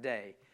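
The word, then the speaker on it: day (δεῖ): The Greek word for must is day, which is, it's spelled D-A-I, but pronounced like day.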